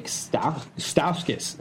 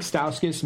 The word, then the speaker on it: Stauskas: Yeah, Nick Stauskas. I can't even say his name. Nick Stauskas, Michigan player, graduated or drafted by the Kings last year.